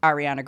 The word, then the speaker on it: Ariana: And then we heard Ariana Grande featuring Zedd with Break Free and Zara Larsson with Lush Life. – Zara, sorry. I probably said Ariana wrong too. – No, you said it right.